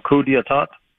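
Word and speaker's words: coup d'état: I don't know if I'm pronouncing it correctly, coup d'etat. – Yeah.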